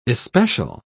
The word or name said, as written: especial